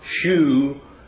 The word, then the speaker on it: shew: The Greek word that is translated proclaim here in the New King James Version and all of the modern versions is translated show or shew, S-H-E-W, which is really an obsolete way of English, but it's pronounced that in the King James Version.